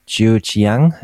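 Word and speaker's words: Jiujiang: hilf mir bitte bei der Aussprache, Jiu Qiang. – Ja, das kann man so sagen, wie, ja.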